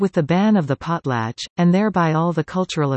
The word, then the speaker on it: potlatch: ban, pronounced as potlatch.